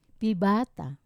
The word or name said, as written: pibata